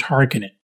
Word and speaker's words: Harkonnen: Now, if you've heard any of my Journey to Arrakis specials on the Dune universe, that you know that Frank Herbert's son, Brian Herbert, said it was Harkonnen.